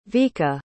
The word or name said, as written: Vika